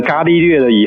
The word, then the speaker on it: 伽利略: 那我们现在念成伽利略了以后，就比较接近他原本的名字这样子。 – 哦，是。好，伽利略呢，他是第一位拿。拿改良过的望远镜去观测天空是吗？ – 嗯，没错。因为其实伽利略呢，